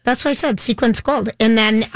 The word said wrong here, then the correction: sequins: Which is a sequence of events, opposed to sequins.